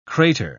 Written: krater

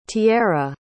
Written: tierra

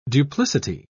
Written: duplicity

